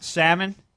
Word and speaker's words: salmon: No, but you know actually it has the best salmon? Is that how you say it? I always say salmon because there's an L in there and people get mad at you.